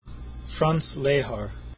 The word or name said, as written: Lehar